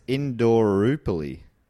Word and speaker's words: Indooroopilly: It Indooroopilly? – Indooroopilly, is it? – I think so. Great. Unless you said it right and I'm saying it wrong and she's laughing at us. – Well, phonetically, it's Indooroopilly.